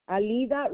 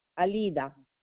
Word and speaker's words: Alida: Alida Rivero.